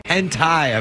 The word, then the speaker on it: hentai: What is it, that hente? – Hentai, I believe, is how it's pronounced.